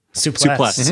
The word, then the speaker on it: souplesse: Souplesse.